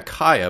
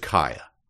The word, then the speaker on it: Kaaya: I thought it was, yeah, Kaya, but it's spelled K-A-A-Y-A. – Kaya. Yeah. So that always trips me up. – Yeah. – But it's just Kaya.